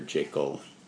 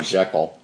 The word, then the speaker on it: Jekyll: If I remember the short story right, it really gets into the internal conflict within the character of Dr. Jekyll. I say Jekyll because that's how they pronounced it in the movie. Not the way everybody pronounces it now, Jekyll.